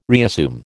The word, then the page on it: reassume: American English